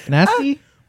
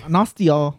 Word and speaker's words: Nastya: Nasty? – My thing is N-A-S-T-Y-A. Oh, Nastia. – Yeah. That's how you say it. – Wait, N-A? – N-A-S-T-Y-A.